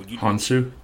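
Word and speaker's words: Hounsou: Jaimin, I always say his last name wrong. So Hansu. – There you go.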